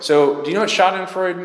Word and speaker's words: schadenfreude: I was a German major, and in German we pronounce it schadenfreude, and I have no idea if in English you pronounce it which way, one or the other.